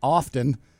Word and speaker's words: often: One little mistake you make is pronouncing the word often. – I make lots of mistakes, by the way. – Often? – The T is silent.